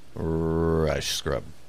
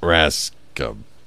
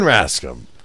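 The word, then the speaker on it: Rajskub: You don't say the J at all? – I don't know, man. Google how to pronounce it. Someone's saying it on video. – Mary Lynn Rice Scrub. Let's see. Mary Lynn Ratscom. – This is some great show prep. – Hey, got her name right, though. Let's see. It's got to be Rascom. Mary Lynn Rascom.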